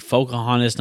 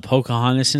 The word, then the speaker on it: Pocahontas: It's supposed to be Pocahontas, not Pocahontas.